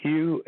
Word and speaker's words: Hugh: My name is, if you put it on YouTube, it's Hugh, H-U-G-H. The last name is Charlson. It's spelled T like Tom, R-A-U-L-S-E-N like Nancy.